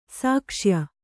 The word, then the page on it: ಸಾಕ್ಷ್ಯ: sākṣya